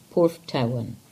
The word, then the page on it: Porthtowan: English